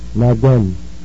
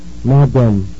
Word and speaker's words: Madame: Count out a 1-2 rhythm and open your mouth wide as you pronounce both of the vowels. – Now done. Madame.